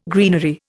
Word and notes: greenery: Indian

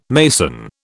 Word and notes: mason: British English

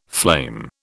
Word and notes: flame: British English

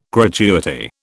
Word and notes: gratuity: British English

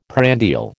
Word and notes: prandial: American English

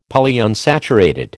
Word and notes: Polyunsaturated: American English

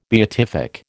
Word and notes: beatific: American English

